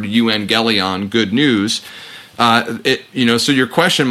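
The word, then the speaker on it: euangelion (εὐαγγέλιον): It's a Greek word that's pronounced euangelion, and we can directly translate that as good news.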